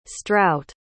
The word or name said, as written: strout